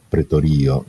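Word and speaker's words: pretorio (πραιτωρίῳ): Però leggere pretorio sarebbe più esatto, pretorio in questo caso, perché l'accento è sullo iota.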